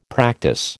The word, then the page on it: practise: American English